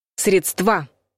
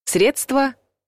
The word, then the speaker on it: средства: Неважно, о чем идет речь, о деньгах, в виде транспорта или чем-то еще, произносить нужно всегда одинаково. Средства. Ударение падает на первый слог. И никак иначе.